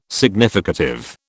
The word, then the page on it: significative: British English